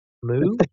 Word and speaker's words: Mu: The Mayan Empire, the Atlantis Empire, the Egyptian Empire, the Mu Empire, like Mu, like a cow.